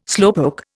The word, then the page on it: slowpoke: Indian